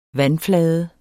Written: vandflade

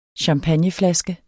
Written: champagneflaske

